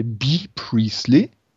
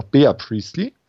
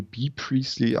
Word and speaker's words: Bea Priestley: Da hat man mal angefangen mit Britt Baker und äh, wie sie ja ausgesprochen wird, B. Priestley. Hm. Also ich nenne sie immer noch Bea Priestley, weil so geschrieben wird. Aber soll ja wohl B. Priestley ausgesprochen werden.